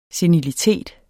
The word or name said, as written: senilitet